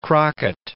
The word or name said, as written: Crockett